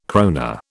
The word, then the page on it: krona: British English